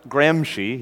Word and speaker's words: Gramsci: Antonio Gramsci, pronounced Gramsci, he's Italian, G-R-A-M-S-C-I, Antonio Gramsci and the shift to cultural Marxism.